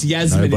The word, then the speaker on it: Yasmin: Well, oh, it's Yasmin. – Nobody cares. – It's a soft J.